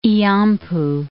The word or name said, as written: Illampu